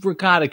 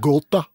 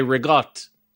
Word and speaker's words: ricotta: It's pronounced, my family would say rigotte like that, and then no one knows what you're talking about. And then I had to unlearn that.